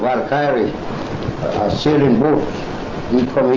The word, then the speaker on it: Valkyrie: Valkyrie.